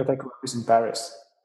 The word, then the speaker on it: Dataiku: Florian Douetteau is the CEO and a co-founder at Dataiku.